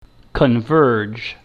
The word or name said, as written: converge